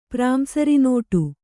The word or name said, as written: ಪ್ರಾಂಸರಿನೋಟು